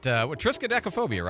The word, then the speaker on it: Triskaidekaphobia: Triskaidekaphobia, right? Isn't that how you say it? – Yeah, that sounds right.